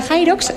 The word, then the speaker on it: Hyrox: subcampeona del Mundial de Hyrox.